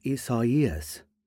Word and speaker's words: Isaias: It's not Isaiah with an S on the end. It's Isaias. It doesn't rhyme with Reyes.